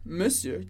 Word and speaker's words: monsieur: Mas ela é uma palavra que mudou muito no decorrer do tempo. Então, a gente fala monsieur. Monsieur. Duas vezes o som e, monsieur.